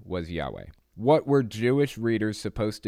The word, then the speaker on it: Yahweh: It's usually thought, though, that when pronounced, it was Yahweh.